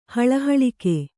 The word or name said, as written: ಹಳಹಳಿಕೆ